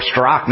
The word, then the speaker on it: Straka: Well, this is more evidence to why Brendan Straka, or Strock, now I've heard his last name without the A at the end, even though it's spelled that way,